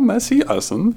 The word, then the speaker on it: Messe Essen: Messe Essen. That's not a German accent. – Messe. Ja, Messe Essen.